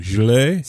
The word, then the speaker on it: Gilets: Jaunes? – That's what I heard. I went on YouTube to watch pronunciations on this, and that's what they said, man. – Gilets? – Sounds just like that.